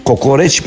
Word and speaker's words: kokoreç: Kokoreç mi kokuruç mi kelimesinden bak telaffuz bile edemiyorum. Tekrar ediyorum. Kokoreç mi kokoriç mi tartışmasından başlayan bir şeyin nerelere kadar gidebileceğini göreceksiniz.